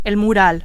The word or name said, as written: mural